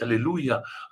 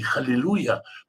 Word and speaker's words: аллилуйя: Да, я говорил, когда говорил о слове аллилуйя, что важно произносить аллилуйя, а не халилуйя, потому что халель в данном случае, да, это осквернение. И халилуйя – это звучит, пусть Бог оскверняет Всевышний. Аллилуйя, это славьте.